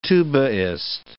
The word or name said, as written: tubaist